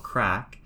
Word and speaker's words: craic: C-R-A-I-C, which for us is Irish for fun. It's called crack. But if I say crack, you're going to think I'm saying something else. But C-R-A-I-C is pronounced crack for us.